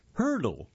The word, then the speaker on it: Heardle: It's titled, Wordle Launches New Game for Music Lovers Called Hurdle. And that's spelled H-E-A-R-D-L-E.